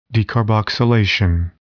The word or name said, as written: decarboxylation